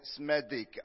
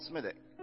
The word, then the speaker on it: Smethwick: You know, I once went into, into, into Smethwick and I called it Smedic. And the, an old lady stood up and said, You don't pronounce it that way. It's called Smethwick.